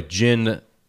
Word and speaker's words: Djinn: and by the way, Djinn spelled like a genie, like what is it? D-J-I-N-N or something? – Mm-hmm.